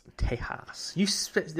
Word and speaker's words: Tejas: Tejas. That's actually how you pronounce it correctly.